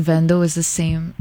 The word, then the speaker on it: Vendo: Vendo. – Oh, Vendo. – Pati pronunciation mali. Yung V naging B.